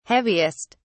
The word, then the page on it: heaviest: British English